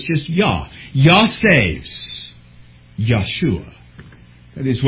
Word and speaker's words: Yeshua: And so very few actually would pronounce it Joshua, but it's going to be Yahshua, or perhaps you have heard Yeshua. That's the Hebrew name, Joshua, as we say it. In Greek, that becomes Iesus, which we pronounce Jesus. Again, we just love that soft J, apparently.